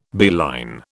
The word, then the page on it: beeline: British English